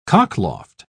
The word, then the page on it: cockloft: British English